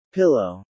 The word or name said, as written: pillow